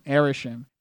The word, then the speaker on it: Arishem: Arishem.